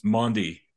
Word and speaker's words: Maundy: Am I pronouncing that right? – It's Maundy, right? Maundy.